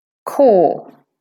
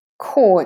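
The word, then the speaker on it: corps: Core. Core, it's a French word, and we don't say the P or the S there, actually. Core.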